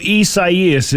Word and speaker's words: Isaias: So Isaias is the correct pronunciation of it.